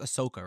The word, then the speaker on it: Ahsoka: I think it's pronounced Ahsoka, right? – Yeah.